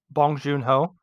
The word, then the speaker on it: Bong Joon Ho: Bong Joon Ho.